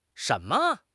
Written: shen2 me5

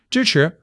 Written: 支持

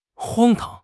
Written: 荒唐

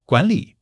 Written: guan3 li3

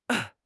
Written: 出身

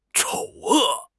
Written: 丑恶

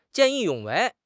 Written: 见义勇为

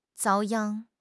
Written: 遭殃

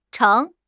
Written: 橙

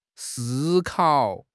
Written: si1 kao3

